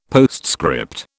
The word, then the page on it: postscript: British English